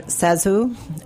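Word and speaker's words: ZaSu: Oh, so she actually pronounces her name Zazu, which is totally counterintuitive, but she was a silent screen star who had these enormous, enormous eyes and sort of a pouty face.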